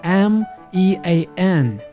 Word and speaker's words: mean: mean 这个词，注意 e a m e a n 这个单词中间 e a 发音是长音 e，面带微笑的动作 mean。